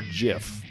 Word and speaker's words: GIF: Is it pronounced GIF or JIF? – It's GIF.